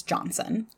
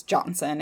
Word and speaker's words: Johnston: And so at this point, Mr. Bell decided to confide in family friend James Johnson. – Okay. – And those of you who look this up, it is spelled Johnston, but it is pronounced with the traditional Scottish pronunciation, so it is Johnson.